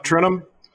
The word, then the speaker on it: Trentham: I say Trentham. So, I almost say it like Garrett, a TH is silent.